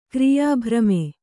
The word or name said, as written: ಕ್ರಿಯಾಭ್ರಮೆ